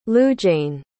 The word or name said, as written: Lujayn